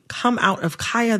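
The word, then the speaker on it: Khia: I am going to, in this conversation, pronounce Kaya, the rapper, with the long I. Kaya, okay? My neck, my back, mm-hmm. for those who need a refresher. Kaya, okay? Mm-hmm. The Kia Asylum is a mispronunciation of her name.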